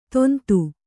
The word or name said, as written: ತೊಂತು